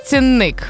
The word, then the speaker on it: цінник: Окей, перше слово – цінник. – Так. – Я знаю, що там наголос на ик, тому що є віршик «Цінник нікуди не зник». Це щоб запам'ятати.